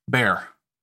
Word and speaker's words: Béxar: The Revolutionary Army settled into what would become known as the Siege of Béxar, or as the Anglos in the country had begun to pronounce it, Bear.